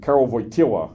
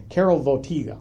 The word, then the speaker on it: Karol Wojtyła: And when we know this now because the documents are available, but when Karol Wojtyła,